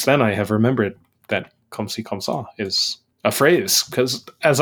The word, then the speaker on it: comme ci, comme ça: Nice pronunciation on that one, though.